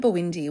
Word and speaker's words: Bwindi: And the term Bwindi, which I did look it up, some people don't pronounce the B, but most people pronounce the B, so I'm going with that.